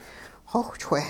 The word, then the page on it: xowhchwe': hupa